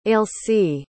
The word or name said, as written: ailsie